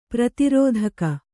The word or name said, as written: ಪ್ರತಿರೋಧಕ